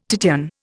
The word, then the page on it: Titian: Indian